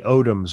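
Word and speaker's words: Odomes: It was Nate Odemus for the Buffalo Bills. I think I'm pronouncing his last name right. – I think it was Nate Odoms, wasn't it? – Odoms.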